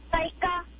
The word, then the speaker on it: Ryka: Hi, my name is Raika, and I think the answer is race car. – What's your name again? – Raika. – How do you spell it? – R-Y-K-A.